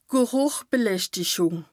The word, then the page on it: d' Gerùchbeläschtichùng: Bas Rhin